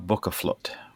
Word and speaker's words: Bókaflóð: Jóla Bókaflóð, which is an Icelandic tradition of giving books to each other on Christmas Eve.